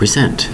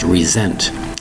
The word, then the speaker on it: resent: It's pronounced resent. The S sounds like a Z. Resent.